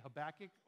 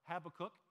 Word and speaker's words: Habakkuk: And hey, pronunciation of Habakkuk, whether you say Habakkuk or Habakkuk, you hear it both ways. From what I've been able to figure out, they both work, so call it either way.